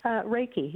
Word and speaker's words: Reiki: And I also see here that you do a lot of energy work, and I believe it's pronounced Reiki. Is that correct? – Reiki, yes.